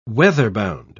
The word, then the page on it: weather-bound: British English